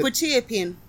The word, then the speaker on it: quetiapine: Oh, yeah, it's quetiapine. – Quetiapine. – Like K-W-E.